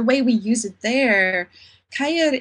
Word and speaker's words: kuier: A cure? – Yeah, it's actually pronounced Kair.